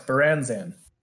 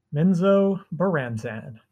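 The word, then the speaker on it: Menzoberranzan: It's hard to pronounce. Baranzan. – Minzo-Branzan.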